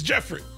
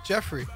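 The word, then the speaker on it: Geoffrey: Oh, so the E and the O is? – It's Jeffrey. – It's silent. – Jeffrey. – Jeffrey.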